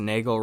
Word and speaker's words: Nagel: Also, if this somehow makes it on the podcast, Nagel rhymes with bagel.